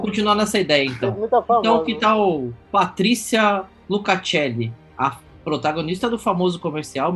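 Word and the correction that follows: Lucchesi: Patrícia Luquezzi. Luquezzi. – Luquezzi, obrigado. – É, Luquezzi. Obrigado pela pronúncia. Para mim era Luca, Luca, Lu, é Lucacelli. É Luquezzi. Luquezzi.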